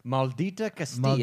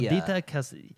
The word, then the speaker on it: Maldita Castilla: We also have I can't even pronounce that. – Maldita Castilla. – Maldita Castilla.